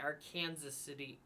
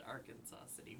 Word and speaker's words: Arkansas City: It was Kansas, not Arkansas, but it was in the town of Arkansas City, a.k.a. Arkansas City, if you're from Kansas, but we're outside of Kansas, so we would pronounce it Arkansas City.